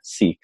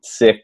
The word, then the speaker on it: Sikh: And fast forward years later, I'm really deeply interested in the Sikh tradition, or Sikh, some people pronounce. But so just so you don't get confused, if you're listening, sick, not S-I-C-K, the tradition of being sick, but the tradition of being a-sick, which is a student of the infinite.